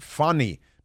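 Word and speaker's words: Fani: No, you have to pronounce it Fanny because that's how the Swahili pronounce it.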